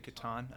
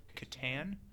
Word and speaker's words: Catan: I'm glad that you said it, like Catan. There's a big, I don't know if you know the issue around how it's said. Some people like to say it Catan. – Yeah, I'm not. – Which I believe is the incorrect way to say that.